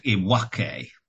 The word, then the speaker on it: iwake: It's called iwake.